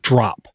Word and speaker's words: DRAWP: It's pronounced drop. Drop for school. But it's spelled D-R-A-W-P for school.